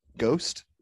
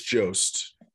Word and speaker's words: Jost: I thought I saw it with Colin Ghost. How do you pronounce it? – I think it's Jost.